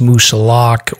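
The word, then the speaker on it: Moosilauke: you can pronounce Moosalock or Moosalocke. – Right. Yeah, I agree. – Neither one is wrong, but it sounds like Mark has an opinion that I should pronounce it a particular way.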